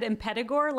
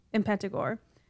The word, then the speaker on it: Impetigore: Impetigore? Yeah. I've heard it said Impetigore, like almost they say the T like a D, but who knows?